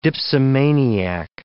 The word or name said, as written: dipsomaniac